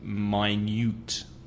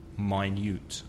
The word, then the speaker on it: minute: So in the adjective minute, it's my, which is similar to I or y or tie. And the second vowel sound, you, like you, the letter, or you and me.